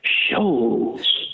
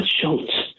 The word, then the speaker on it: Scholz: Tom wants you to know that his last name is pronounced Scholz, not Schultz.